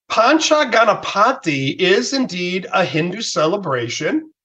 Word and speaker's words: Pancha Ganapati: I believe it's called Pancha Ganapati.